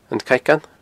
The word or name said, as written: An Caigeann